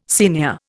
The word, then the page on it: senior: Indian